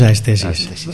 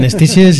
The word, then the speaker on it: Aesthesis: Nosotros demos aesthesis. Otros dicen estesis, estusis y lo que sea, aesthesis, no pasa nada.